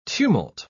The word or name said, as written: tumult